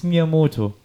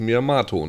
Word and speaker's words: Miyamoto: Ja, dann ist Miyamoto. – Ja, aber nicht, ist bestimmt falsch. Nicht Miyamato, ne? – Ne, hier ja.